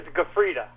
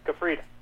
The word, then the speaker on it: Gufreda: Well, it's my last name is Gufreda. Gufreda.